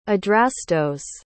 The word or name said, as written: adrastos